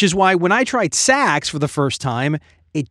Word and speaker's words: Saxx: I recently discovered this brand of underwear called Saks. That's spelled S-A-X-X.